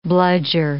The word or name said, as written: bludger